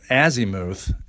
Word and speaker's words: azimuth: It's A Z I M U T H. So you would think it was pronounced azimuth, and it's azimuth. Okay, go ahead. So azimuth when you're taught to read